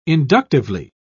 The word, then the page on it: inductively: British English